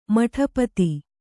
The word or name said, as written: ಮಟಪತಿ